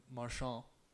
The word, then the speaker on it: Marchand: Just quickly, Brad Marchand.